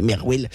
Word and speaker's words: MRWL: Et en fait, Marwal, c'est l'acronyme MRWL. Du coup, moi, au début, que je connaissais pas trop Marwal, je me suis dit, mais comment on prononce ça? En fait, c'est juste, on peut prononcer Merwell.